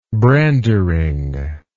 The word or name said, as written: brandering